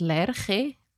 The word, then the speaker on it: Lerche: I think it's pronounced Lerche. – Lerche. – Because it's a German word.